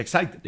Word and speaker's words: Exide: Se escribe Exide, se pronuncia Exide, decía el comercial de las baterías.